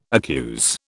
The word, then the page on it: accuse: British English